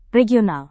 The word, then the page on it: regional: indonesian